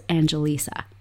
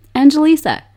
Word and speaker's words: Angelisa: I have such a tricky name, Angelisa. That's four syllables in my name.